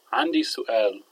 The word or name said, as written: 3andi su2al